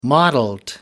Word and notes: mottled: American English

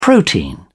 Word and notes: protein: British English

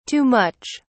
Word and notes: too much: British English

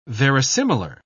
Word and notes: verisimilar: British English

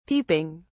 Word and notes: peeping: British English

